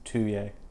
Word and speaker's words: Tullier: Tullier.